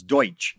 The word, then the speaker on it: Deutsch: Well, they called him Dutch here in America because no one could pronounce Deutsch.